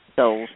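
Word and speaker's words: Soules: Souls, yeah.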